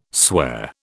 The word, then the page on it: sware: British English